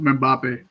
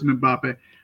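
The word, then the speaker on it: Mbappé: You know, and before I keep going forward, I believe that Kylian's last name is pronounced Mbappe. If I'm wrong, the internet will correct me, but I'm pretty sure it's Mbappe.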